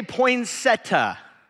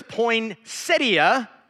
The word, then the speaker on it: poinsettia: So, the word, how many of you say poinsettia? Okay, some of you grew up in Northeast Ohio like I did, maybe. The word is poinsettia.